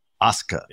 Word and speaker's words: Asuka: So I am going to try very hard to remove a full syllable and vowel and say it without the U, Asuka.